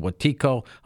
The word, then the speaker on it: Wetiko: His latest work is called Wetiko.